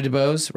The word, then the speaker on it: DeBose: Ariana DeBose, right? I'm pronouncing that correctly? – Yeah.